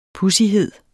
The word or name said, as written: pudsighed